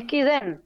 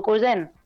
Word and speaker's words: Küyen: Ah, es Kuyen o Kuyen.